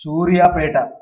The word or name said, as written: Suryapet